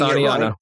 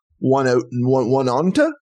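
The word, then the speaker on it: Oneonta: And it's the one city that if you actually read it on paper, you'd be like, one onta? – Here's my side note to this, because I work the industry I work in, and you have insurance adjusters that do not live in the state that call in, and you should hear them try to pronounce Aniana. It is hilarious. It's usually one Oda.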